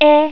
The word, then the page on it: e: hiragana